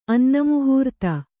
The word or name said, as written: ಅನ್ನಮುಹೂರ್ತ